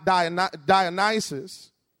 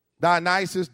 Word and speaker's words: Dionysus: You spell that H-O-R-U-S, H-O-R-U-S, Horus. He's an Egyptian god. Then you got the Greek god Dionysus. Dionysus, D-I-O-N-Y-S-U-S.